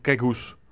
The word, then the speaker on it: Gregus: Øh, det er vi så blevet korrekt siden, han, og han hedder Jan Gregus. Altså sådan mere flad udtale på efternavnet, så vidt jeg kan forstå. – Kort øh, S. Øh, ja, kort S, ikke? Og så med Z til sidst i udtalen.